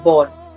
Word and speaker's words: Bård: Bueno, se llama Bar Kolstad. – Yo lo pronuncio igual. – Vale, es el batería que ha estado... – En noruego, cuando ves dos seguidas... es Bård. Pues sí.